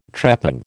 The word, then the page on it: trepang: American English